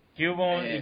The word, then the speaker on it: Cubone: Cubone y Kangaskhan.